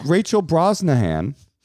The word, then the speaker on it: Rachel Brosnahan: Rachel Brosnahan.